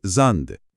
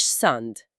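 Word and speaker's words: Sand: يعني كلمة رمل ساند بتتنطق زاند. – زاند مش ساند.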